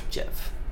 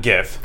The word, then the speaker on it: GIF: So we have to talk about how that's actually pronounced, by the way, which is GIF. – Yes. Not GIF.